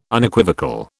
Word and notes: Unequivocal: British English

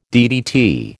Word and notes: DDT: American English

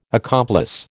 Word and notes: accomplice: American English

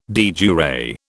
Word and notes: de jure: British English

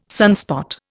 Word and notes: sunspot: Indian

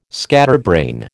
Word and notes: scatterbrain: American English

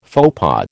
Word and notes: faux pas: American English

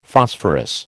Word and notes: Phosphorus: American English

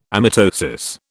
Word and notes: amitosis: British English

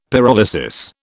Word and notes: pyrolysis: British English

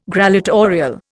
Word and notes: grallatorial: Indian

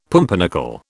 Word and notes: pumpernickel: British English

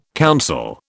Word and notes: counsel: British English

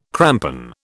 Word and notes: crampon: British English

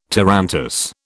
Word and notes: tarantass: British English

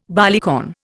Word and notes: barleycorn: Indian

